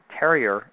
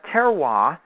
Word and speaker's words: terroir: A nice read on the nebulous concept of terroir, or terrier, if you want to pronounce all the letters. But terroir, as it should be properly pronounced in French, by Harold McGee, of On Food and Cooking fame.